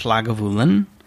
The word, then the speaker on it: Lagavulin: And then the last one that we've not tried yet, I believe, is pronounced Lagavulin.